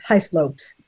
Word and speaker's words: Hislope: She is the mother of Cody Hall, who was, in fact, Brittney Hislope's first love. Hislope's. Am I pronouncing it right? Hislope? – Hislope, yeah. Hislope, yeah.